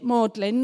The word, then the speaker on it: Magdalen: That's how you say it, Magdalen, not Magdalen. That's how it's spelled, but you say Magdalen.